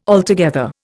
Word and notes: altogether: Indian

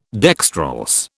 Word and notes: dextrorse: British English

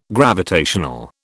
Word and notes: gravitational: British English

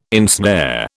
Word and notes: ensnare: British English

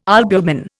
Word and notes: albumin: Indian

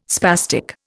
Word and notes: spastic: Indian

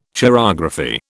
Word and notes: chirography: British English

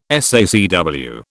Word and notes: SACW: British English

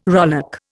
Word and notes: rowlock: Indian

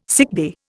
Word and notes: sickbay: Indian

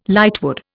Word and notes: lightwood: Indian

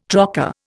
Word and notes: trocar: Indian

